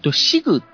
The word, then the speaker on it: SIG: シグっていう名前ですね。